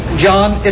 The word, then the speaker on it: Jon: Spelled J-O-N. – I beg your pardon? – My name, John, is spelled J-O-N, not J-O-H-N.